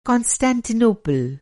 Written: कांस्टैंटिनोपुल

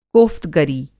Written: कोफ़्तगरी

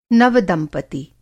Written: नव-दंपति

